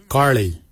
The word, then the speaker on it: giilyi: Darb is spelled D-H-A-A-B. It is a traditional knife, a darb. For boomerang, we say garli. Garli is the traditional name for boomerang. Garli is spelled G-I-I-L-Y-I, garli.